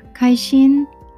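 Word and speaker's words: 카이신 (开心): 카이신 아, 카이 열다 신 마음 심자예요 중국어는 신 신일성을 넣어서 신이라고 나고요. 우리 한국말은 심이라고 읽습니다. – 발음이 비슷하지만 성조가 들어가다 보니까 다르게 들릴 수도 있어요.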